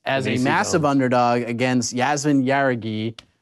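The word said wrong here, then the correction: Jauregui: Hadagi is how we pronounce it, actually.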